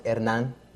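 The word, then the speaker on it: Hernán: My name is Hernan Guadalupe. It's pronounced Hernan in Spanish.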